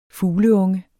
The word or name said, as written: fugleunge